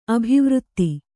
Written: ಅಭಿವೃತ್ತಿ

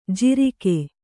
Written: ಜಾರಿಕೆ

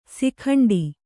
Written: ಸುಕಂಠ

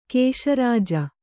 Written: ಕೇಶರಾಜಿ

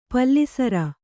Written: ಪಲ್ಲಿಸರ

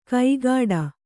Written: ಕೈಗಾಡ

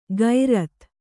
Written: ಗೈರತ್

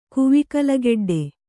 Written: ಕುವಿಕಲಗೆಡ್ಡೆ

